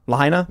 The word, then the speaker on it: Lahaina: Many of you probably saw the news about what's going on in Maui, a major wildfire raising the entire town of, do you guys know how to pronounce it properly? I want to make sure. Is it Lahaina? – Lahaina.